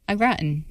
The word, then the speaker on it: au gratin: Is that how you say it? – Au gratin. – Yeah.